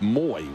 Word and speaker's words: Moe: Spelled M-O-E, Wendell, but pronounced Moy.